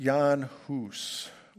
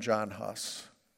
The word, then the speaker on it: Jan Hus: In contrast to Peter, his name is pronounced Jan Hus. We call him John Huss in English.